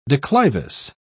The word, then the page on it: declivous: British English